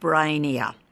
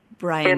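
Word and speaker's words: Breynia: It is called Brainia. – How do you spell that, please? – B-R-E-Y. – Yep. – N-I-A. Brainia. – N-I-A.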